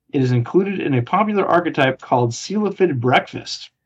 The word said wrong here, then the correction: Cephalid: It's called Cephalid, by the way. – Huh? – It's Cephalid is how it's pronounced.